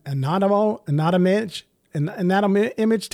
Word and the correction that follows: Anatomage: We've been pronouncing it anatomage. We were just trained by the company's official trainers, and they say anatomage.